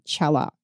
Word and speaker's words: Chella: Chella.